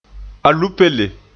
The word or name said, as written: alupele